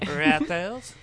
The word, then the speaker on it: Reuters: It's pronounced Reuters. – Okay. – It's French-American. – Okay. – Reuters.